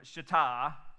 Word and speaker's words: Shittim: Verse 1 early in the morning, Joshua and all the Israelites sent out from Shittim. By the way, that's how that's pronounced.